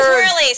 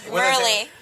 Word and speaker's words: Merli: And my name's Merli. Sorry. Being a dick. – Wait, how do I say it right? – Merli.